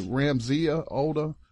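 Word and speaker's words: Rasmea Odeh: Let's transition to, am I pronouncing her name correctly, sis? Is it Ramzia Oda? – Yes.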